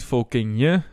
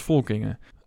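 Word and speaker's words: Folkinge: Het is niet Volkingje, het is als Volkingen. Uitgesproken als de naam Inge.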